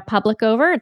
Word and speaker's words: Publicover: So my name is Dana Publicover. It's the two words public and over, put it together.